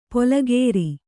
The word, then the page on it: ಪೊಲಗೇರಿ: polagēri